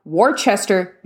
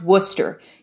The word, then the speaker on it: Worcester: Um, I got really mad living on the East Coast because you have Worcester, nope, pronounced Worcester.